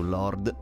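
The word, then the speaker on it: Lorde: Anche se abbiamo avuto un po' di difficoltà a capire la pronuncia del nome, però siamo abbastanza sicuri si pronunci Lord. – Andiamo su Lord, ecco.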